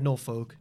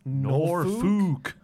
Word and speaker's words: Norfolk: I'd have even let him get away with it if he'd said Norfolk, just because of the way it's spelled. But Norfolk.